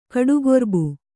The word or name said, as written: ಕಡುಗೊರ್ಬು